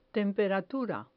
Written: temperatura

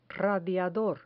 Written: radiador

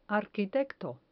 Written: arquitecto

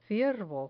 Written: ciervo

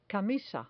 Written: camisa

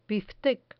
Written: biftec

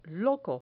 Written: loco

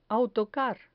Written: autocar

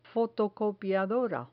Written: fotocopiadora

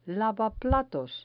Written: lavaplatos

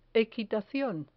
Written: equitacion

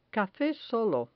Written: cafe solo

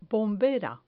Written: bombera

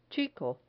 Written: chico

